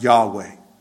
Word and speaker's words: Yahweh: But the four-lettered word YHWH pronounced Yahweh.